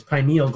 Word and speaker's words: pineal: See, that's what I first thought he said. But no, it's pineal gland.